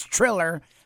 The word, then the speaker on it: Triller: First of all, it's Triller. I know that looks like that's not a real word, but it's Triller.